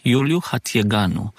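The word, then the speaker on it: Iuliu Hațieganu: The university is named by the founder, Iuliu Hatigheanu.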